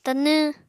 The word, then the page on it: Tanw: Apatani